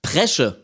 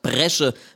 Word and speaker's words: Bresche: Presche mit P statt Bresche mit B ist nämlich für junge Menschen aus dem südhessischen Sprachgebiet gar nicht so unüblich.